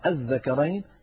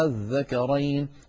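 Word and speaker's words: آلذكرين: نرى هنا المثال الثاني في القرآن العظيم، وهو قوله تعالى الذكرين، هو أصلها الذكرين لام التعريف دخلت عليها همزة الاستفهام، ففي السطر الأول أبدلت ألفا فصار النطق الذكرين، وفي السطر الثاني سهل فصار النطق الذكرين تماما كما مر معنا في الحالة الأولى.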